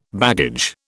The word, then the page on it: baggage: British English